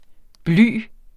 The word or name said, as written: bly